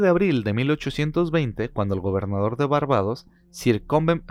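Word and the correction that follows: Combermere: No, se, se llama Cobermer, perdón, Cobermer. Eh, Cobermer.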